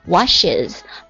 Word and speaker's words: washes: wash 加上 es 之后读作 washes